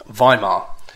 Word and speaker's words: Weimar: Although initially, of course, Berlin was too dangerous, and so the government met in a place called Weimar, or Weimar, as it should be pronounced.